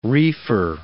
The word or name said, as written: reefer